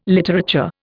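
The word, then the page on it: littérateur: Indian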